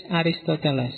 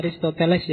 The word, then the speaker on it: Aristoteles: Oke, terus muridnya yang ketiga, cucu muridnya Socrates, Aristoteles. Bacanya Aristoteles ya, bukan Aristoteles. Sok-sok diwaca Aristoteles. Kalau dalam bahasa Arab Aristo saja sebenarnya. Atau ada yang nulisnya Aristatalis. Yes, monggo lah.